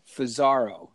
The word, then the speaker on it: Fusaro: The old at symbol followed by F like Frank, O-O, Z like Zed, A-R-O, Fusaro. So it's at Fusaro. It's my name phonetically because everyone pronounces it wrong. It's actually spelled F-U-S-A-R-O, but...